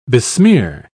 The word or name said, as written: besmear